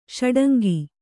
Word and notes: ಷಡಂಗಿ: ṣaḍangi

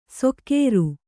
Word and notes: ಸೊಕ್ಕೇರು: sokkēru

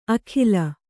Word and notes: ಅಖಿಲ: akhila; kannada

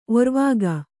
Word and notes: ಒರ್ವಾಗ: kannada

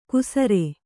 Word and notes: ಕುಸರೆ: kusare

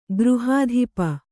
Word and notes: ಗೃಹಾಧಿಪ: Kannada

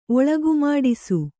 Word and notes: ಒಳಗುಮಾಡಿಸು: Kannada

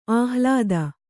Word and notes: ಆಹ್ಲಾದ: kannada

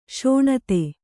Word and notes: ಶೋಣಿತ: śoṇita

